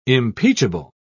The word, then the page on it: impeachable: British English